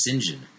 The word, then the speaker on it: St. John: So when you see St. John, it's pronounced St. John.